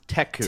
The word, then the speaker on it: Teku: I believe it's pronounced Teku. – Teku. It looks... – It's spelled T-E-K-U.